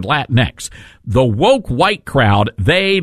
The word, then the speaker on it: Latinx: By the way, I didn't realize I had been mispronouncing that word all this time. Because I've been saying Latinx or Latinx. L-A-T-I-N-X, right? You would think Latinx. But that's not the correct pronunciation. It's Latinx.